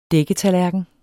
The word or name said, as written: dækketallerken